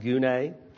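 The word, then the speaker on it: gune (γυνή): Specifically, whether the certain word, it's G-Y-N-E, it would be pronounced gune.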